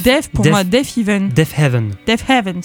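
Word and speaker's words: Deafheaven: Et moi j'ai été, alors on entend actuellement un extrait de Canary Yellow qui est mon titre préféré de Def Even, je ne sais jamais comment on le dit. – C'est Def Heaven. C'est deux haies.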